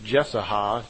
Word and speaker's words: Jesaja: I believe it's pronounced Jesaja, J-E-S-A-J-A. Those of you who are into the history of this may know better than I.